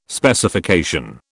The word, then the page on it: Specification: British English